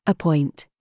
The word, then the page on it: CHOICE: British English